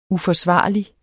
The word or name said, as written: uforsvarlig